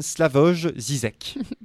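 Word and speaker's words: Slavoj Žižek: comme le dit si justement le philosophe slovène Slavoj Zizek.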